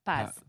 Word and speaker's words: Paz: Is it Paz? – Paz, yeah.